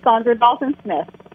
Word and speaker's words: Saundra Dalton-Smith: Sandra Dalton Smith.